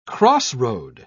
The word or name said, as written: crossroad